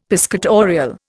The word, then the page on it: piscatorial: Indian